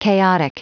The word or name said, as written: chaotic